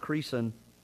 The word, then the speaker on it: Creason: The way that you spell it is Reason with a C at the beginning, and you pronounce it Creason.